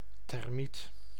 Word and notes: termiet: /tɛrˈmit/

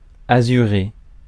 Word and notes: azuré: France; /a.zy.ʁe/

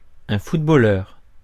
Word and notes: footballeur: [fut.bo.lœʁ]; France

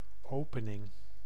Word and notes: opening: /ˈoːpənɪŋ/

